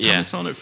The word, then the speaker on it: Upamecano: I just feel if we brought in our equivalent of the Van Dijk, and we've been linked with Upamecano, is that how you pronounce it? – Upamecano from Leipzig.